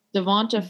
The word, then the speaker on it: DeVonta: Isn't it pronounced Devonta Smith? I don't really know. – Oops. I don't actually know. Devonta Smith. Sure, it's Devonta Smith.